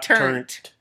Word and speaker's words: turnt: I thought it was turned. – No, not turnt. It means to get hype for a party.